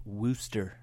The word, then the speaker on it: Worcester: That's spelled Worcester, pronounced Worcester, Mass.